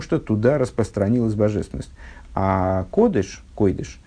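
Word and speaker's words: кодыш (קודש): Э, ну, вот, об, этом, об этом же разница между кодыш и, и, и, койдыш и кодыш. на сифарском произношении, на современном произношении, вернее, кодыш и кадош.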